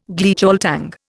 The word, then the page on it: Gleichschaltung: Indian